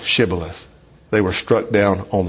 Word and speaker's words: Shibboleth: The Gileadite clans pronounced it with an SH, Shibboleth. But the Ephraimite clans pronounced it Sibboleth. So the difference between sh and s meant someone's life and death.